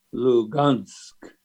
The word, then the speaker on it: Lugansk: The Russian spelling is Lugansk, the pronunciation being Lugansk. So there's quite a bit of difference there.